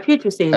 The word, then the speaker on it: putrescine: and I'm going to probably butcher this one, putrescine. – Yeah, putrescine. – As byproduct. Okay. All right, so not too bad. – No, you got it.